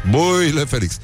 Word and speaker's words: Băile Felix: Băile Felix. Nu? Așa se pronunță.